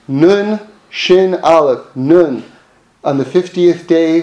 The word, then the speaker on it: Aleph: Now remember the letter Aleph. That's the first letter of Anochi. Is silent. Aleph is silent.